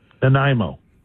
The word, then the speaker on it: Nanaimo: N-A-N-A-I-M as in Mary, O, Nanaimo.